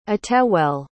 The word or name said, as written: attewell